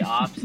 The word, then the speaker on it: OPS: How do you say that stat? – On base percentage and slugging, is OPS. – So you don't say ops. – No.